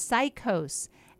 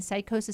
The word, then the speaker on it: psicose: It's allulose, also known as cycose. And cycose is spelled P-S-I-C-O-S-E.